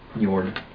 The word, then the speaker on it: Njord: Something that's spelled N-J-O-R-D. – Njord. – Njord? – Njord. – Yeah. – Njord. – The floating church is called Njord's House. – Njord's House. – Njord's Holden. – House of Njord.